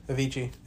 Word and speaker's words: Avicii: Did I say that right? – Yeah, Avicii. And I do consider him a composer.